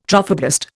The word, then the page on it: trophoblast: Indian